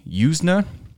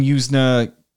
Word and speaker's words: Yuzna: This is from Brian Yuzna. I'm maybe pronouncing his name wrong. Y-U-Z-N-A.